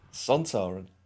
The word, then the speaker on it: Sontaran: That's one in the Sontaran suit. – Yes. You got it right. – Did I say it right? – You said it right. Hey. Sontaran.